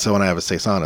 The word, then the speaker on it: Saison: Saison.